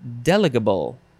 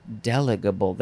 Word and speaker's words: delegable: But the emphasis is on the first syllable. Delegable. I think that's what it is. Delegable.